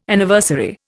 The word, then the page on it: Anniversary: Indian